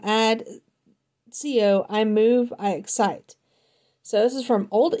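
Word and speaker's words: accite: And I know it sounds like I'm trying to say excite, but I'm saying it wrong, but it's not excite, it's excite.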